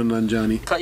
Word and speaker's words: Nanjiani: Camille Nanjiani.